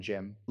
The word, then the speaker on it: Cem: It's K-A-R-S-A-N. – K-A-R. Okay, it's important because it is important. – C-E-M, so C-E-M Gem. – It's really, C-E-M. Yeah, these guys are like the two. – C-E-M.